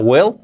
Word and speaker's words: will: El verbo modal will, cuidado que se pronuncia will, no es will con e, no, con i, no, con e, will, así sería, will.